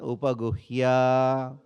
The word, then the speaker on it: upaguhya: Gata vyataha, gata vyataha, upaguhya, pronounce properly, upaguhya.